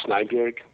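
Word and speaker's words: Snejbjerg: It's a graphic novel that I did a few years back with Peter Snyberg. – Nice. – The guy. – Great. – Is that how you say it? – Yes, that's how you say it. Snyberg.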